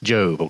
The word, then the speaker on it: Job: So this is, but it is, no E there. But it is pronounced Job, okay?